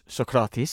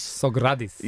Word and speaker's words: Sokratis: Well, I remember I'd done the first show with you, and I was never quite sure how to pronounce Sokratis. – Sogradis.